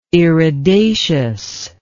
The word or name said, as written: iridaceous